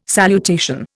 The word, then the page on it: salutation: Indian